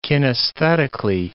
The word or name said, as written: kinesthetically